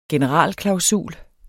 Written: generalklausul

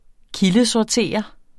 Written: kildesortere

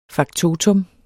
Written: faktotum

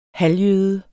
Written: halvjøde

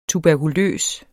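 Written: TUBERKULØS

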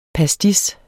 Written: pastis

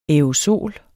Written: aerosol

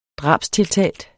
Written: drabstiltalt

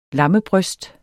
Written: lammebryst

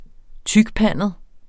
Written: tykpandet

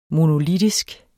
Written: MONOLITISKE

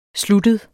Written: sluttet

